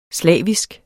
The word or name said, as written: slavisk